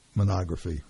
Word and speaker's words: mammography: But I know we've been talking why it's important, but why is it important to schedule mammography. How do you say that? – Mammography.